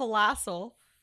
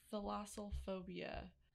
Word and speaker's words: thalassophobia: Correct way to pronounce it. – I guarantee it's not called thalassal. – Thalassophobia. – Oh, gosh. How did you get this? Phobia. Or explain what it... – Just listen to it. – Thalassophobia. – Thalassophobia. Okay, so you kind of said it right.